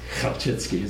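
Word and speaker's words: Chelčický: this Kalchitsky, is how you pronounce it.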